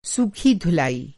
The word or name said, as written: सूखी धुलाई